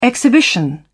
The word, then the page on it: exhibition: British English